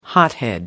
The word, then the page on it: hothead: American English